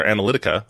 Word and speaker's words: Analytica: Facebook blocked Cambridge Institute from their platform Friday, so only a couple days ago, because it turns out Cambridge Analytics, or Analytica, I've been saying that wrong the whole time, Analytica, lied about deleting the information.